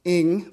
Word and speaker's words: Ng: If we haven't met before, my name is Matt Ng. That's how you pronounce my last name.